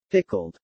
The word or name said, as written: pickled